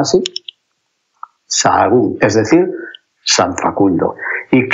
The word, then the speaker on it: Sahagún: Claro, y la F, pues que la gente estaba cansada de trabajar en el campo pues dejaron de pronunciar la F y dijeron Sahagún. Y para escribirlo, pues hay una H por el medio y ya está.